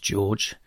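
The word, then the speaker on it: Georg: His full German name was George without an E, which, as far as I can tell, is still pronounced George.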